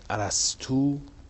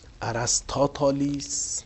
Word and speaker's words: ارسطو: کلمه ارسطو به گونه های مختلف تلفظ میشه. ارسطو که گویا مخفف است، ارسطاتالیس با تخفیف رسطاتالیس و با تخفیف بیشتر رسطاتالیس و ارسطوتالیس. البته پیش ما مشتهر است به ارسطو، یعنی همون مخففش مورد اشتهار قرار گرفته.